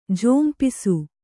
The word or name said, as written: ಝೋಂಪಿಸು